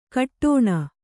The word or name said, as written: ಘಟ್ಟಣೆ